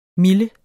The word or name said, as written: mille